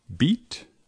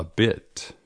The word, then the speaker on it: bit: Which is it, beat or bit? The answer is B, a bit, a bit, un poco.